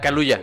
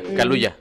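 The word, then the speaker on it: Kaluuya: Es que creo que Daniel Cuyao, o cómo, cómo se dice, el de Get Out. – Ah, Caluya, Caluya.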